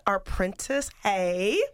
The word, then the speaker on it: Arprentiss Haye: It's Sunday morning, so and we are talking with Arprentiss Haye of Arprentiss Well Fit, right? – Yes.